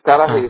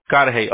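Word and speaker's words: Cudahy: Y otra cosa que le quería preguntar es, aquí veo que el negocio está, no sé, tengo un address de 4137 Kudahi Street. No sé si es... – Es Karahei Street, ajá. – Karahei, okay. No sé cómo, no sabía cómo pronunciarlo, okay.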